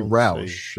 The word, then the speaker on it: Roush: Actually, Roush is the way you pronounce that.